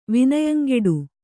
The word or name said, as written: ವಿನಯಂಗೆಡು